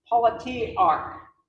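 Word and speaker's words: politarch: It's pronounced politiarch. Politiarch. Politics, arc, with arc on the end of it.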